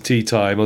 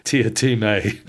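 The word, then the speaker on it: Teatime: And I keep wanting to call him Mr. Tea Time, although he insists it's pronounced Tiatime. – That is a running joke about how it's pronounced, that he says Tiatime and everyone else calls him Mr. Tea Time.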